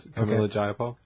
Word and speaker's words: Pramila Jayapal: Yes, Pramila Jayapal.